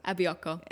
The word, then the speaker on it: Abioko: And it's called, I'm going to mispronounce this, Abioko. Abioko, yep. Which my understanding is means the feeling that you get after, like, you've had a big meal, like that kind of...